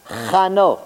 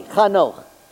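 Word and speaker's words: Chanoch (חנוך): How do you say Enoch in Hebrew? Anybody know? Chanoch. Oh, boy, you know too much. Okay. Okay. Chanoch.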